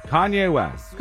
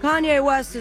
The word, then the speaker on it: Kanye West: Rhymes with Fonlay Mest. – Kanye West. – Kanye West is right.